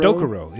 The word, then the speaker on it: Dokuro: Is this Dokuro or Dokuro? – Dokuro, yeah. – Dokuro.